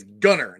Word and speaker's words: Gunter: You know, Jennifer Gunner was on my show, and she told me it's Gunner, not Gunther.